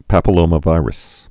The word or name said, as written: human papillomavirus